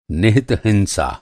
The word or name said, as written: निहित